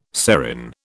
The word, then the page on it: serin: British English